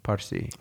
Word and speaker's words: Parsi: So, you know, I just, I want to ask you what it was like being Parsi. Am I saying it right? – Parsi.